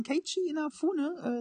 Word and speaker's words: Keiichi Inafune: Und zwar, das nächste Spiel ist vom Herrn Kaijia Inofune. Kennt ihr, kennt ihr nicht, gell? – Nee. Was? – Ja, aber ich kann es auch nochmal richtig aussprechen. Äh, von Keiichi Inafune, äh, so der ist mit dafür verantwortlich.